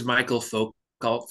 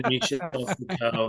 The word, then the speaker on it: Michel Foucault: Oh, I thought it was Michael Foucault for a long time. – Yeah. – Somebody mentioned Michel Foucault.